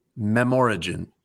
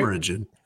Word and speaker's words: Memorigin: I believe that this is pronounced Memorigin, like a combination of memory and origin. Mem- Memorigin? – Right. Memorigin.